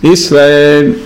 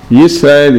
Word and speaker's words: Yisrael: Yisrael is written with Yud. So it's a common mistake. People usually, the way they pronounce it, sound like an Aleph. They say Shema Israel. It's not Israel, it's Yisrael.